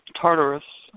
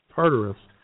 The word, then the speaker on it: Tartarus: It's Tartarus. And Tartarus, sorry.